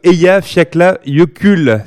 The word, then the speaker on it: Eyjafjallajökull: C'est le Eyjafjallajökull.